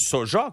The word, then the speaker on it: soja: Tu sais, ça s'écrit qu'un J, puis on dit un Ya. T'sais, on dit un Y, faut que c'est de la sauce soja qu'on devrait dire. Parce que moi, j'ai jamais appris dans mes cours de français qu'il fallait prononcer un Y au lieu du J.